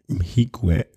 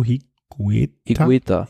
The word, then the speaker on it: Higuita: Und ja, also Simon Yates vor Sergio Higuita, den kennt man euch. Wie spricht man das aus? Sergio Higuita.